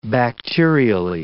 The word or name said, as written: bacterially